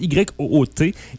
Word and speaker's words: Ute: Donc, Sleeping Ute, oui, ça s'écrit UTE, tu sais. Genre Sleeping UTE. – UTE. – Mais ça se prononce YOOT.